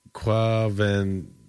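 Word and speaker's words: Quvenzhané: According to IMDb, her name is pronounced Quvenzhane. Jane Kova. Yeah, I guess. Whatever. – It looks like the accent is on the Ven, so it would be Kovenjane.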